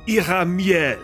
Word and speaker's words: Iramiel: Iramiel? How did you say it? – Iramiel. – Is there a phlegm in your throat when you're doing that? – Well, the R sound in the Menorees is way back in the back of your throat.